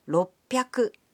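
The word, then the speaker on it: ろっぴゃく: But the pronunciation can be a bit tricky. 300 is pronounced sanbyaku. 600 becomes ropyaku. And 800 is pronounced hapyaku. On to thousands.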